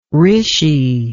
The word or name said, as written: rishi